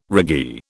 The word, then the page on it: raggee: British English